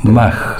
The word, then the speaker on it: Mach: Lo presentás así, 1909, Mach, creo que se pronuncia. – Mach, sí. Te agradezco la corrección.